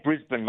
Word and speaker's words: Brisbane: I don't think I said that right. Is it Brisbane? Brisbane? How do I say it? – Well, we just say Brisbane, mate. That's how we talk in Australia.